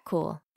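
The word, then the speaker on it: Kuhl: I'm Kat Cool,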